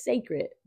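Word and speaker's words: Cécred: It's sacred, but it's spelled, the way it's spelled is C-E with the accent, C-R-E-D.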